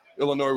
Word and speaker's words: Illinois: Illinois, where the S is silent.